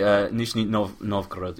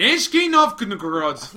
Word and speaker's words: Nizhny Novgorod: How do you say Nizhny Novgorod? – Let me see. Nizhny Novgorod. Yeah, well, Novgorod. – Nizhny Novgorod.